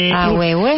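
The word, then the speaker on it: ahuehué: Ahue, mira, ahue con H dos H's intercaladas y acento en la E. – Ahuehue.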